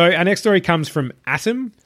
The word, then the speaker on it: Atom: Atom, A-T-O-M. – As in Adam Bomb.